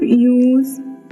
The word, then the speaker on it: use: In the verb use The S sounds like the S in choose